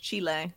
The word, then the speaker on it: Chile: Now, I say Chile, yeah. You say Chile, but I actually prefer your pronunciation because it sounds more fancy. – Yeah, well, it's that's how you say it in Spanish, Chile. – So, Chile. – But most people like to say like chili in English, like the pepper. It's not like that.